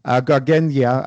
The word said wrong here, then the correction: Garganega: I was wondering if you could explain a little bit about what that grape means and what that grape should taste like. – The Garganega is our...